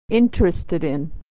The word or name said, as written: interested in